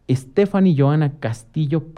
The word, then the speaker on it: Stefany Joana Castillo: Y bueno, tenemos a Stephanie Joana Castillo Padrón. – Hola, muy buenas tardes. – ¿Sí? ¿Lo dije bien? – Sí.